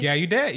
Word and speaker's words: Gallaudet: Remember when we were at Gallaudet? Is that, am I saying it right? – Gallaudet, yeah.